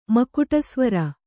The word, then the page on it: ಮಕುಟಸ್ವರ: makuṭa svara